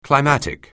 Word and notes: climatic: anglais

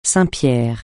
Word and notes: saint-pierre: francais

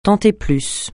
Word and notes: tant et plus: francais

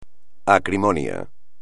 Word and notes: acrimonia: espagnol

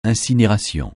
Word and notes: incinération: francais

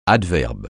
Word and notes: adverbe: French

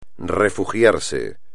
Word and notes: refugiarse: espagnol

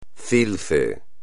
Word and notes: CILCE: espagnol

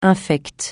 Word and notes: infect: francais

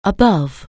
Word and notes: above: anglais

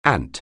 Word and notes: ant: anglais

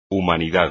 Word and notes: humanidad: espagnol

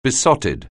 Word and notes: besotted: anglais

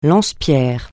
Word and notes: lance-pierres: francais

